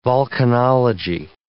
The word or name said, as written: volcanology